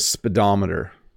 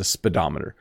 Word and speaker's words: speedometer: It measures your speed, but it's a speedometer. So even though it has the word speed in it, it's not pronounced the same way. So it's a speedometer. Speedometer. You barely say the first little bit, speedometer.